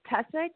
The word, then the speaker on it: Tessa: My name is Tessa, T-E-S-S-A.